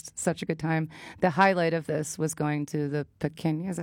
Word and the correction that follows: Palenque: Am I saying this right? Palenque.